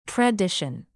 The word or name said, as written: tradition